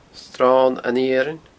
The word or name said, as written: sronaniarainn